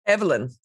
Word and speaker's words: Evelyn: Evelyn. – Evelyn. Oh, okay. Sorry. – I don't really mind. It's right. I think Evelyn's the English pronunciation.